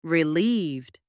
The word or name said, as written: relieved